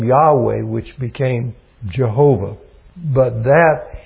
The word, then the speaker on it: Yahweh: but it's Yahweh was the original way that it was pronounced,